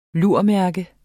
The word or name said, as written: lurmærke